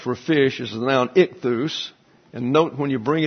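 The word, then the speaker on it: Ichthus (ἰχθύς): It's ichthus. Ichthus, actually, there's some accent is on the last syllable.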